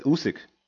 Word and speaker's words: Usyk: Versus, we discussed it with Alexander Usyk. – Mm-hmm. – I said that correctly, right? – Yeah, you said it. Usyk.